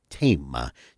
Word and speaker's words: Thema: There, I believe their pronunciation is Tema, spelled T-H-E-M-A in English. In Italian, T-H-E-M-A.